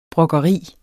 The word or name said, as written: brokkeri